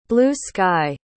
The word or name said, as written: blue-sky